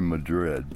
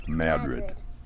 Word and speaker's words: Madrid: Some people say Madrid, but locally it's Madrid. Get rid of your madness in Madrid.